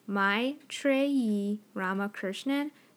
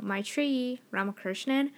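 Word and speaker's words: Maitreyi Ramakrishnan: And how you pronounce her name is Maitri Ramakrishnan. So, Maitri Ramakrishnan.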